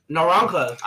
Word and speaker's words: naranja: It says naranja.